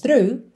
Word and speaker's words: through: Through, lembra que, embora tenha muita letra aí, né, muitas letras nessa palavra, é simples a pronúncia. Through, through.